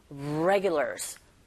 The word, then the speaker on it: regulars: regulars, regulars. regular가 아니라 regulars 이렇게 발음하시고요.